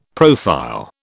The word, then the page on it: profile: British English